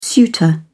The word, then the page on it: suitor: British English